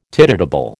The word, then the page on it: tidetable: American English